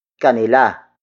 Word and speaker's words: kanila: That would be kanila. Kanila. Kanila. Kanila is spelled as K-A-N-I-L-A.